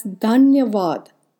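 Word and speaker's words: Dhanyavad (धन्यवाद): Digging into few Sanskrit texts, which is one of the ancient Vedic languages, I found out that the word thankful is commonly said as Dhanyavad. It's spelled as Dhanyawad, and the word grateful is commonly said as Abhari, Abhari.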